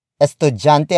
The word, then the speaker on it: estudyante: Estudiante, estudiante, estudiante is spelled as E S T U D Y A N T E. It means student. We borrowed it from Spanish.